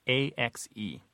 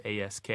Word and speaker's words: ask: A lot of people have reverted back to the pronunciation of ASK.